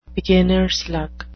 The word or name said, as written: beginners luck